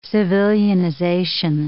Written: civilianization

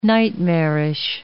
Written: nightmarish

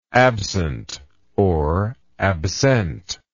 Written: absentness

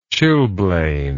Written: chilblained

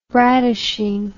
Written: brattishing

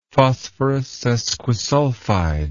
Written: phosphorus sesquisulfide

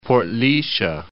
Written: Port Laoise

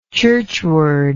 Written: churchward

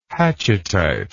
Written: hatchettite

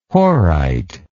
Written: horite

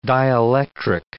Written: dielectric

